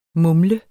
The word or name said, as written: mumle